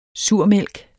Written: surmælk

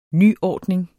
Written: nyordning

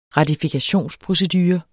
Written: ratifikationsprocedure

